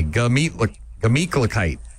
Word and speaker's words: Gemütlichkeit: I'm going to get you to pronounce it properly. Here we go. Gah. – Gah. – Meat. – Meat. – Cluh. – Cluh. – Kite. – Kite. Gluh meat. – Not a gluh. Gah. Just G-A. Gemütlichkeit.